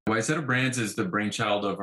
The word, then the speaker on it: Wayzata: Wayzata.